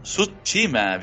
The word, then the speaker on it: Tsushima: Pero viejo, bien, Ghost of Tsushima.